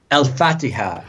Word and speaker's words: Al-Fatiha: One of your teachers, Colonel Mark Conroe, taught you the Al-Fatiha? Is that how you pronounce it? – Al-Fatiha.